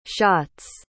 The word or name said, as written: shotts